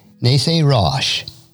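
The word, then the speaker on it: Nesarosh (נשיא ראש): The Hebrew behind chief prince is pronounced Nesarosh.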